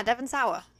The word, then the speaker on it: Devon Sawa: So then we have Anton, who's played by Devon Sawa. I think that's how you say his name. – Yeah, Devon Sawa.